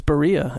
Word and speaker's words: Berea: Berea! – He's with Charlie Fry down there. I think that's where he's been. – Yes. – Is that not how you pronounce it? – That is Berea, I believe.